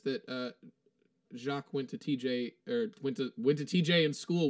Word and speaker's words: Jacques: I pronounced it right. It is Jacques.